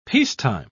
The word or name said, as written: peacetime